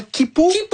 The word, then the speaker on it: Quipu: Et si vous pensiez que c'était déjà vertigineux d'imaginer la Niakea, attendez qu'on vous parle de comment Kipu. Ça s'écrit Q-U-I-P-U. – Oui. Donc Kipu, c'est deux fois plus grand que la Niakea.